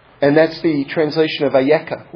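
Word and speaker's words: Ayeka (איכה): That's how you spell Eicha. It's pronounced there Ayeka, but it's the exact same word.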